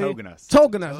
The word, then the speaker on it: Tauginas: Did I pronounce that correctly? – Tauginas. – There we go.